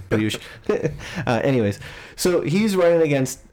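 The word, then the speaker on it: Piyush: His real name is like Priyush. – 15 syllables. Oh, yeah, it is Priyush. – Is it Priyush? – Is it Priyush? – Yeah, it's Priyush.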